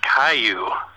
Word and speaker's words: Caillou: Hey, it's pronounced Caillou. The bald-headed cancer patient child cartoon is Caillou.